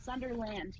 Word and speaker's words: Sunderland: My name is spelled exactly how it sounds, Sunderland.